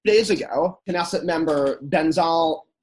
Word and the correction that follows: Bezalel Smotrich: forgive me for butchering this name, Smotrich. – Bezalel Smotrich. – Yeah, Bezalel Smotrich.